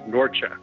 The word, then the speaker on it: Norcia: Well, Nathan, you pronounced it correctly. It is Nursia, by the way. Although today the same town is still there in the Italian mountains, but they call it Norcia, N-O-R-C-I-A.